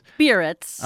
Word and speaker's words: Biarritz: Biarritz.